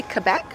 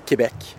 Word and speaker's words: Quebec: Am I saying it properly, Quebec? – No. – Oh. – No. – How do you say it? – Quebec.